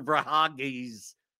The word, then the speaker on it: Verhaeghe: But like, you know, I could look through and it's like, yeah, maybe I can't pronounce Carter Verhaeghe's name.